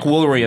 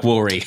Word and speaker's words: Woolery: What did they rhyme with Woolery? Tomfoolery? – Tomfoolery! Yes, they did! They rhymed Tomfoolery with Chuck Woolery.